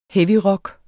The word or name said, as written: heavyrock